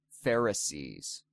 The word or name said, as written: Pharisees